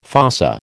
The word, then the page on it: fossa: American English